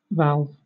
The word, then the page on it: valve: British English